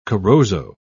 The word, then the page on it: corozo: British English